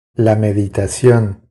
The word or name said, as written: La meditación